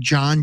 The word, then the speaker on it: Jon: It's my full name, Jon, J-O-N, without the H. My middle name, Alan, A-L-A-N.